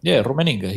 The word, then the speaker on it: Rummenigge: Roman Inga, I can't pronounce his name. He's one of the directors from the Bayern Munich. Rummenigge.